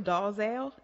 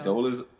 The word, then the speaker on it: Dolezal: Rachel Dalzell, is that it? Is that how you pronounce it? – Dalzell. – It's Dolezal. – Isn't it Dolezal? That's what we'll say. Let's say Dolezal.